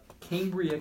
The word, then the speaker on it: Cambria: Uh, what about Cambria County? Am I saying that right? You are.